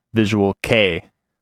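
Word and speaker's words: Visual Kei: They corrected that it is pronounced Visual K. Spelled K-E-I, which they know from, quote, TikTok and Zoomers.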